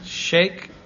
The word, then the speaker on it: Sheik: I-L-D-E-R-I-M, Shake Ilderim. – Shake as in S-H-E-I-K? – Yes. – Yeah. – Shake. As opposed to S-H-A-K-E, which would be a...